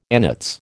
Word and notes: annates: American English

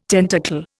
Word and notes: tentacle: Indian

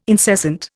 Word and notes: incessant: Indian

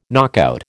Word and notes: knockout: American English

